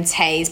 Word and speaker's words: Toews: It's kind of written like a script, which I think you would love that, by someone called Miriam Tayes, but it's spelled T-O-E-W-S.